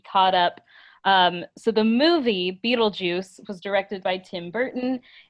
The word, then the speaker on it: Beetlejuice: In the movie, they're played by Alec Baldwin and Gina Davis, who become ghosts haunting their former home, and an obnoxious, devious poltergeist named Beetlegeist, pronounced and occasionally spelled Beetlejuice.